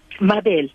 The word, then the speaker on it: Mabel: MABEL.